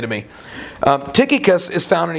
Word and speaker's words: Tychicus: Number six, Tychicus, and I think you pronounced that one right too. Tychicus, which means fortunate one.